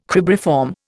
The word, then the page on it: cribriform: Indian